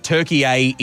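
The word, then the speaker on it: Türkiye: Turkey is the Western sort of pronunciation of it all, but Turkey A is the traditional way to say it, and it's been officially recognised by the United Nations since about 2022, I believe.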